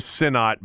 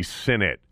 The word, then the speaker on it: Sinit: I thought it was Sinnott, but I went and looked at the – you know, how it was pronounced, and it's actually Sinit. S-I-N-I-T is the way it's spelled out phonetically.